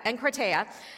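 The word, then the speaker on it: enkrateia (ἐγκράτεια): Another example where this verb is used, enkrateia, and enkrateia, I got it, enkrateia. I listened to it on Google pronunciation like 16 times.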